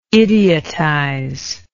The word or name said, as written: idiotize